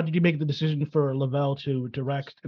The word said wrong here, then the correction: Lovell: And I hope that's Lavelle. It's Lavelle. – Lovell Holder. – Lovell. – The emphasis is on his first syllable of his name.